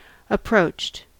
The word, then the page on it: approached: American English